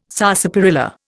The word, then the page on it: sarsaparilla: Indian